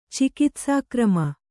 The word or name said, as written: ಚಿಕಿತ್ಸಾಕ್ರಮ